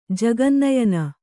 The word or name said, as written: ಜಗನ್ನಯನ